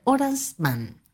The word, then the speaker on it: Horace Mann: Mr. Horace Mann. ¡Qué bien que lo pronuncia, Y de su esposa, la señora Mary Peabody Mann, también pedagoga, pero enseñaba matemática, botánica, química, enseñaba de todo.